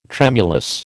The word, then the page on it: tremulous: American English